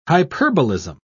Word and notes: hyperbolism: British English

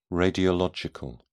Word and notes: radiological: British English

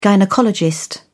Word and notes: gynaecologist: British English

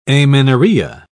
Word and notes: amenorrhoea: British English

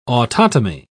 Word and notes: autotomy: British English

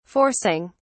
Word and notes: forcing: British English

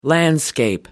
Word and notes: landscape: British English

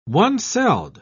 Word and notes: one-celled: British English

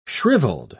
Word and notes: shrivelled: British English

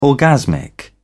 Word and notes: orgasmic: British English

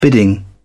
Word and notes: bidding: British English

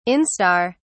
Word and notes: instar: British English

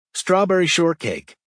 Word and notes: strawberry shortcake: American English

